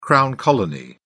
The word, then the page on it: crown colony: British English